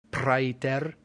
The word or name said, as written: praeter